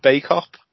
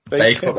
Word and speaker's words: Bacup: So joined us to promote Tommy and Tobias's wrestling show in the Circle in, I want to say it right. – Go on. – Bake-up. – Bake-up, that's right, yeah. You're right. Yeah. – So it looks like back-up, but yeah. I knew it wasn't.